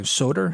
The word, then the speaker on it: Söder: Söder? – Yeah.